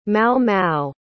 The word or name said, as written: Mau-Mau